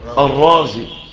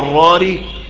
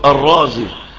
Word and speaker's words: الرازي: ده بيحدث ثقل في النطق، ومن هنا لما جم ينسبوا الى الري اختاروا ان هم يقولوا الرازي، فيحطوا على احدى الرائين نقطه عشان ما بقاش اتنين راء الراري، لا يبقى الايه ها الرازي، فيبقى ده يسهل عليك الايه النطق.